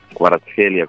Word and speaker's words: Kvaratskhelia: eppure Kvaratskhelia, come si dice, se l'ho detto bene, sì.